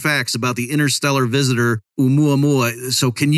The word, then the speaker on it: Oumuamua: Oumuamua. Is that a decent pronunciation? – Yes, that's perfect.